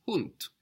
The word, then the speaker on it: Hund: But in German, the everyday word for dog is Hund, hound.